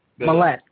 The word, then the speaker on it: Mallett: Mallett. Kind of rhymes with the shaver.